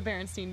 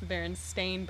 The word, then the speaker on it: Berenstain: Yes, except that it's not the Berenstein Bears, it's the Berenstain Bears.